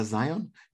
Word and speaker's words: Zeihan: And my recent obsession is a guy from America called Peter Zeihan, Zeihan. It's spelled weirdly, but Peter Zeihan is his name.